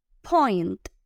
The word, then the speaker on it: point: Point. Otra vez, muchachos, que suene esa T final. Es muy importante cuando pronunciemos en inglés que las consonantes finales les pongamos mucho énfasis.